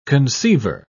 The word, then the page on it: conceiver: British English